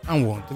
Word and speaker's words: Unwound: Ci lasciamo con un ultimo brano sempre scelto, Jan Wound, io non riesco a pronunciarli mai. – Unwound, vedi, dovevi stare tu al posto mio, io al posto tuo.